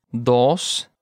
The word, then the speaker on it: dos: Now remember, folks, when you're pronouncing the O in uno and dos, you have to use a Spanish O, right? Uno, dos. Not the American O, right? It's not dos or uno. It's uno and dos.